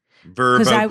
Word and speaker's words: VRBO: I found a great VRBO, pronounced as you wish. Thank you very much. – Verbo, please.